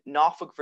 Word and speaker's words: Norfolk: One is in Norfolk, Virginia. And yes, I said it Norfolk. It is pronounced N-A-W-F-O-L-K, even though it's spelled N-O-R-F-O-L-K.